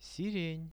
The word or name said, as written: siren